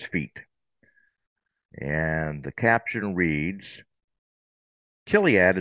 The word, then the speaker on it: Chiliad: When you look at it, you would probably pronounce it Chiliad. But it's really not pronounced that way. And by the way, the category is Crimes Against Humanity, The Great Awakening. It's Right. – actually pronounced Kiliad. So the CH is a hard K. Kiliad.